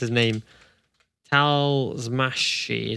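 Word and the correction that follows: Talmácsi: is it? I'm absolutely butchered it. There you go. Gabor Talmashi.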